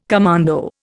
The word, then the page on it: commando: Indian